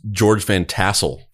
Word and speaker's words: George Van Tassel: Now I'm looking at his name and I'm like, is his name George Van Trussel or is it George Van Tussel? Hold on. I think I said it wrong. – This is George Van Tassel.